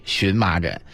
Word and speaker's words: 荨麻疹: 其实大家对那个字儿吧，不用太纠结啊，那个字儿，是表示那个药的时候念前麻，然后如果是这个麻疹的时候就念荨麻疹，